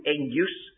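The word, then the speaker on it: engus (ἐγγύς): This emphasis on the making near, this particular word, I'll give it to you, engus, although you wouldn't spell it with an N, E-double-G-U-S, if you want to try to pronounce it at all like the Greek, it's E-N-G-U-S, the same as the word anchor, there's no N in it in the Greek.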